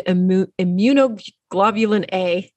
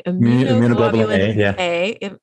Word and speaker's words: immunoglobulin A: immunoglobulin A, if I can say it right. – Immunoglobulin A.